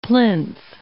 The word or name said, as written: plinth